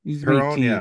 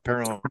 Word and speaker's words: Perron: Is it Perron? Is it a French name? – Yeah. – Perron, yeah. Okay, so it would be pronounced Perron here.